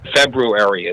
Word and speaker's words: February: Just like February, it's not February. The R is said kind of quickly, so it might come across as February, but the R should be pronounced.